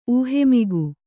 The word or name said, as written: ಊಹೆಮಿಗು